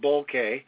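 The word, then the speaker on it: Boelcke: Bolchek. I'm not sure if I'm pronouncing his name right or not. – The guys at Top Gun who adore him call him Oswald Bolke.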